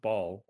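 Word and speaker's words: Bhaal: The was the Throne of Baal or Bale, I guess. Depending on the game, it's that name gets a different pronunciation.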